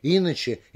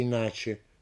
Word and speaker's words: иначе: До сих пор никто не может с уверенностью сказать, как будет правильнее произнести мышление или мышление, иначе или иначе, творог или творог.